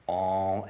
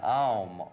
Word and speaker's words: Om: Om and Aum, Om, spelt in the in English, O-M is pronounced as if it were spelled A-U-M,